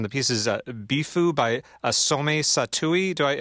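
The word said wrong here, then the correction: Satoh: That sounds pretty close. I think the last name is pronounced Sato.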